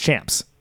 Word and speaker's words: C4AM95: So then you change it to C4AM95 was how it was spelled, but it was Champs, pronounced Champs.